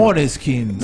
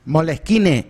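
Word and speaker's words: Måneskin: Moleskine sería. – No, Maneskin. – Moneskin. Se escribe Maneskin, pero ellos lo pronuncian Moneskin.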